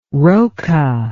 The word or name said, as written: roca